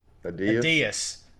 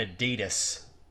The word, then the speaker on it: Adias: How do you say his name? – Adias. – Okay. – Which would be butchered into Brian Adidas. – Oh, nice. – But it was Adias.